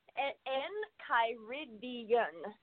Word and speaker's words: Enchiridion: Endocrine, is that the proper pronunciation? It means like a manual, right? – Hang on, my buddy boy. Enchiridion.